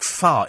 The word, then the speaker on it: Fach: I think far is F-O... There you are. Click on Saucepan Fach. That's what it looks like, but it's pronounced far.